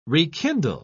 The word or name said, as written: rekindle